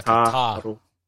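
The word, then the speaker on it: tākaro: The first A has a, it's like a double A. – Yeah, like Takaro. Yeah.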